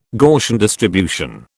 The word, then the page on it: Gaussian distribution: British English